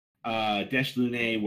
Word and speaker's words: Dash Lune: Dash Lune, where Lune is pronounced more like moon.